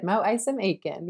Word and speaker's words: Mo Isom Aiken: Mo Isom Aiken.